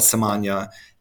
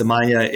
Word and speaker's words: Samanea: And by the way, for those keeping score, it's spelled Samanea, and it looks like it should be Samanea, but it actually rhymes with lasagna.